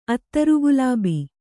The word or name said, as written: ಅತ್ತರುಗುಲಾಬಿ